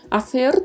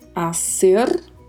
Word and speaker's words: hacer: Hacer tem uma particularidade né, de pronúncia, que é a letra C, que pode ser pronunciada com som de S ou com o som gerado com a língua entre os dentes, né? Pode ser, então, hacer ou então hacer.